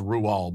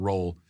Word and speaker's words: Roald: Yes, it is pronounced Roald, by the way, not Roll.